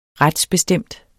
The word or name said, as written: retsbestemt